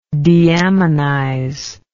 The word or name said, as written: deaminize